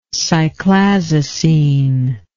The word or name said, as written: cyclazocine